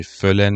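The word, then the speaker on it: füllen: برای نمونه واژه فلن میشه پر کردن، ولی فیلن میشه حس کردن.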